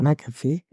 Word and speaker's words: McAfee: Oder dass du einfach in der Show von mm, Beth wie McCaffey, ich kann das nicht aussprechen. – Beth McAfee? – Ja, genau, danke, dass du quasi irgendwie live bei seiner Talkshow dabei bist.